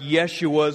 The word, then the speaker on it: Yeshua: Jesus is the Greek form of the Hebrew word Joshua, actually, which is pronounced Yeshua,